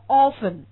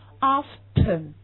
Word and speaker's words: often: 这个词是个双音节词，我们读成 often，但是你要知道这个词还有个读音读 often，你看它有 often，那么它就两个读音，对不对？